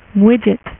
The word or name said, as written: Widget